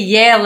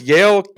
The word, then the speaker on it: Yael: Yael, yeah. You can say Yael like the university. It's fine.